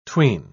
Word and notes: 'tween: British English

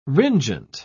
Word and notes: ringent: British English

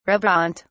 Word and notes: reboant: British English

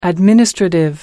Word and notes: administrative: American English

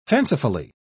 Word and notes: fancifully: British English

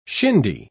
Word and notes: shindy: British English

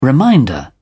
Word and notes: reminder: British English

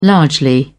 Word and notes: largely: British English

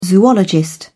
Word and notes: zoologist: British English

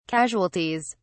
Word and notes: casualties: British English

